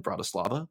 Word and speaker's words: Bratislava: Bratislava.